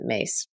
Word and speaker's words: Myss: Again, Carolyn Mace, it's M-Y-S-S, but she pronounces it Mace.